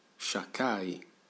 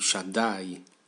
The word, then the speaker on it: Shaddai: In me il tuo nome è chiamato Shakai. Si pronuncia con Dalet D invece di K. Quindi Shaddai.